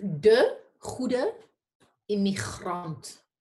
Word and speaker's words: De Goede Immigrant: What is the Dutch pronunciation of the title? – It's De Goede Immigrant. There's a lot of clearing your throat involved.